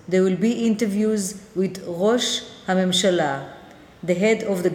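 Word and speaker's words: Rosh (ראש): The word Rosh, spelled Resh Aleph Shin, you don't hear the Aleph is silent, but you still have to write it.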